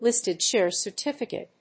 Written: listed share certificate

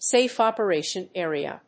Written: safe operation area

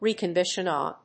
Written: reconbination

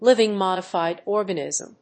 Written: living modified organism

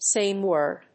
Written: same word

